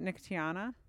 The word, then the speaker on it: Nicotiana: I pronounce it Nicotiana.